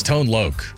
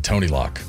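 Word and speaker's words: Tone Lōc: It's Tone Loke, and I think I said Tony Locke.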